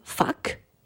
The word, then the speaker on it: FAKK: Alors, je précise, fuck, ça s'écrit F-A-K-K, car on pourrait entendre autre chose lorsque le chanteur entonne puissamment ce refrain.